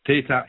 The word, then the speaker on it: Theta: T-H-E-T-A. Theta or Theta, it depends how, there are different pronunciations for it.